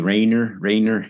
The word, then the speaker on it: Raynor: But, you know, it's more often said Rayner with an emphasis on the E. Raynor, you know.